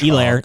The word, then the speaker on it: Hilaire: Elair, Elair, the H is silent.